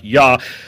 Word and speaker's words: Yah: It should be pronounced Yah, Yahweh.